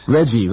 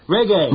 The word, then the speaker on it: reggae: Reggie. No, that's not how you pronounce it. – Raggy? Reggae. – Reggae, yeah, that's it.